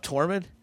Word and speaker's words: Tormund: But you brought up Tormund. Yeah. Wait, is that how you say his name? The Wilding. – Yeah.